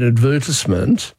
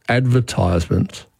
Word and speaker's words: advertisement: but the thing that we advertise I call an advertisement. – Mm-hmm. – Again, yes. Another of our producers routinely says advertisement. – Yes, and that's a more recent pronunciation, but still around for a couple of hundred years, and it would come about because of the verb advertise. – Right. – And it's been given a spelling pronunciation, but advertisement was the one that was recommended when the word first appeared.